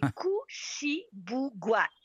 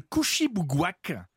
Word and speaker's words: Kouchibouguac: C'est Kouchibougak ah. – Alors, ah. je le redis, le parc national de Kouchibouguac au Nouveau-Brunswick.